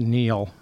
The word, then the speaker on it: Niall: spelled Niall, but I think it's actually pronounced Neil. It's sort of an Irish hybrid.